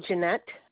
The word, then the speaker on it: Jeanette: Jeanette. It's spelled J-E-A, two N's, E-T-T-E, middle initial is P, and the last name is Sleeper.